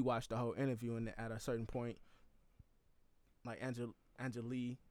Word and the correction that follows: Angela Yee: Angela Yee.